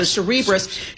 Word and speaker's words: Cerebras: How do you say your name? Like Cerebral. So Cerebras shares soaring.